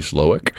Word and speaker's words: Slowik: Buddy Slowick, S-L-O-W-I-K is how you spell that last name.